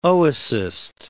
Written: oocyst

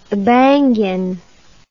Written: abangan

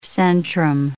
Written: centrum

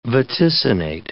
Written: vaticinate

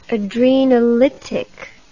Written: adrenolytic

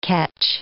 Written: ketch

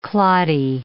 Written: cloddy

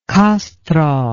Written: kastro

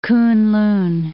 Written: Kunlun

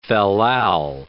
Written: fal-lal

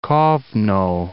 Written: Kovno

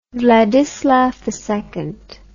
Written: Wladyslaw II

